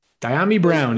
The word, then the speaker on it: Dyami Brown: Feel correct on the pronunciation, but is it Dyami Brown? – Dyami Brown, yeah.